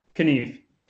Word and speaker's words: Kniff: And you say your last name, Knife? – Kniff. – Kniff. Okay. You actually do pronounce it with the K.